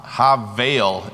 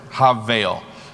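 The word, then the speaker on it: Ha-veil (הבל): Ha-veil is how you say that word. Ha-veil.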